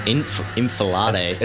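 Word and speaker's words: enfilade: I think it's Alifade. Is that correct? – The N comes first, though. Infilade.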